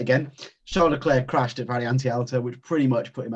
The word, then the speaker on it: Variante Alta: Variante Alta, is that right? Is that how you say it? – That was very good. – That was very good. Yeah, that sounded right.